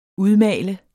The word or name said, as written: udmalende